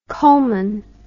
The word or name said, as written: coalman